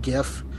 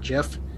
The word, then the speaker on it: GIF: I'd love a good GIF, which that is how you pronounce it. I'm going to pronounce it anyway, GIF, not JIF.